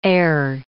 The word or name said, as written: heir